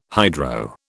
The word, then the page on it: hydro: British English